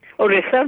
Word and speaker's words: Orissa: Orissa, right.